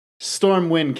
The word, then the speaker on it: Stormwind: It's Stormwind, right? I'm saying that right? Stormwind? – Yeah.